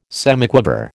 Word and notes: semiquaver: American English

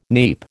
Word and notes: neep: American English

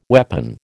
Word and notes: weapon: American English

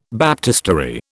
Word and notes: baptistery: British English

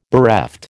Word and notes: bereft: American English

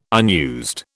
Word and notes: unused: British English

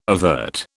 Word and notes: avert: British English

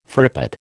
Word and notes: frippet: American English

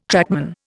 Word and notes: trackman: Indian

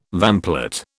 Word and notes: vamplate: British English

